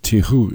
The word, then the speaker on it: Tijoux: If you listen real closely, you'll hear the lead singer, who is Ana Tijoux, singing, might be pronounced Tijoux in Spanish, singing 1997.